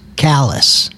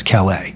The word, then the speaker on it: Calais: And that's such a, it's such a deep, deep moment to pronounce Callis as Calais, because it takes you into the entire history of the French speakers in this area versus the English speakers, right.